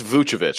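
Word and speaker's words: Vučević: it's Vucevic because they call him Vuce, Vucevic. so I just say the Avich uh, uh, after that, and I think that's right.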